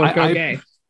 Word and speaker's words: agoge: I always read it as sort of a goge or something. And then I heard actual scholars pronounce it and they're like, a goge. I was like, no, this is not what you're calling this.